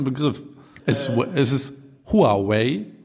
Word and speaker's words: Huawei: Genau, also was ich gesehen habe äh, oder was ich gehört habe, ist, dass sie beide äh, Aussprachen akzeptieren, aber die chinesisch richtige Aussprache ist Huawei.